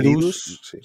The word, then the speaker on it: Reedus: Norman, Ridus. Reedus.